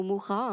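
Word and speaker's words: Mucha: C'est une œuvre remarquable de Hokusai qui a été réinterprétée par un artiste contemporain qui s'inspire à la fois de Musha, donc Musha qui se prononce Musha ou Mura hein, selon le pays,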